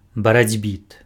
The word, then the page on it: барацьбіт: baracʹbìt